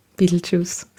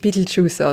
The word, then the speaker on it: Betelgeuse: Ich sage so gern den englischen Namen, Betelgeuse. Das klingt einfach so cool. Ist natürlich ein arabischer Name in Wirklichkeit. Aber auf Englisch spricht man ihn tatsächlich Betelgeuse aus.